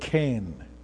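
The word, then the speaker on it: ken (חן): And this word is the word ken. Ken. If you are going to spell it in English, you will spell it like K-H-A-N-E. In the Hebrew, it's like C-H-E-N, but the pronunciation.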